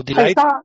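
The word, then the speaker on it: de Ligt: It's De Legit? De Legit? – Oh, Delight? Delight.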